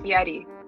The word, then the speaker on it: Fieri: It's actually pronounced Fieri. Oh. Or um, those of you who are curious, but I pronounce it um, Fieri just to you know appeal more to the common folk.